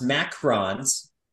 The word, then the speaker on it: macaron: But I'm going to take the one spelled M-A-C-A-R-O-N, not the O-O-N. It's pronounced macarons.